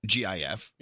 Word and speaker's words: GIF: He says the animated looping image program he built is pronounced GIF. – GIF. – With a soft G.